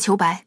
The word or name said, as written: Qiubai